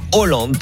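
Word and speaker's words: Haaland: Hollande, parce que les deux A, ça se prononce O.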